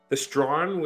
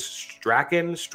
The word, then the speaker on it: Strachan: Wait, is that the Strawn, which Strawn Avenue is named after? The one that's always mispronounced? I think my favorite is Strachan, all of those.